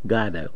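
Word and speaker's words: Guaidó: Well, I just wanted to talk about Venezuela and his opponent, Caldo, I believe. How do you pronounce his last name? Caldo? – Guaido.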